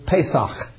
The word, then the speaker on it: Pesach: The festival of, as it is pronounced in Hebrew, Pesach, or Passover.